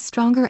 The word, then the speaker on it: stronger: These are the words stronger and strongest. Notice how I used a hard G sound when I said them.